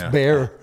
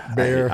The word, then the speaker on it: Bexar: And it's Bexar. Fuck. – It's Bear. – Bear.